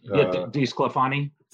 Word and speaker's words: DeSclafani: You know, just looking even at this weekend with Logan Webb and DeSclafani, I think, if I'm pronouncing that. – DeSclafani.